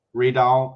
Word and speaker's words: Rydell: I mean, for English speakers, just replace the Y with two E's. – Rydell, and then it's close. Then you're close enough. – Rydell.